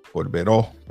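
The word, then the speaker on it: Corberó: Tenemos a la baroness, que es Úrsula Corbero. Corbero, perdón, con acento en la O.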